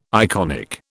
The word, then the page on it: iconic: British English